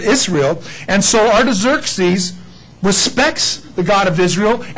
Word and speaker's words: Artaxerxes: The guy that was ruling them at this particular time, and if you can't pronounce that, don't worry about slobbering, his name is Artaxerxes. That's how you say that. Artaxerxes, well, actually, his full title is Artaxerxes Longimanus, but anyway, of Persia, he writes a decree on this date.